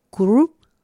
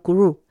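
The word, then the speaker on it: kurü: El negro es el kuru, ¿cierto? Ahí es como una doble R la pronunciación, pese a que se escribe con una R y tiene los dos puntitos en la letra U al final.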